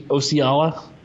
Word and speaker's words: Osceola: It's Oci- Osceola County Stadium.